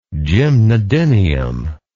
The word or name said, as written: gymnodinium